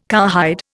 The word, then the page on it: cowhide: Indian